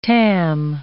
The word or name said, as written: tam